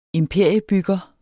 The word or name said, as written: imperiebygger